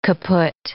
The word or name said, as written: kaputt